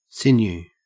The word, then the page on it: sinew: Australian English